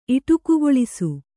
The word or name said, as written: ಇಟುಕುಗೊಳಿಸು